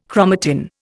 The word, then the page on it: chromatin: Indian